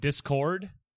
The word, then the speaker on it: Discord: So the way that Zyber pronounces Discord sometimes is like Discord. Like he puts the emphasis on the cord part, which is fine.